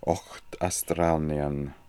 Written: okhtastralnian

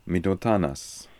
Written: midotanas

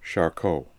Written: Charcot

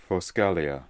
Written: foscalia

